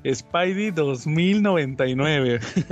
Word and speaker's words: Spidey 2099: Aquí, aquí Spidey 2099, veo que ya lo pronuncian correctamente. Spidey 2099.